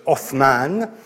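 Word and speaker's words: Othman (عثمان): prononcé en arabe, avec le « th » de « Othman », donnait aux Occidentaux, et les Occidentaux à l'époque c'était surtout des Italiens, une sonorité de « th » qui est par la suite devenu un double « t ».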